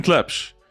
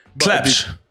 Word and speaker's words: Tlepsh: It's exactly like it's written Tlepsh. – Tlepsh.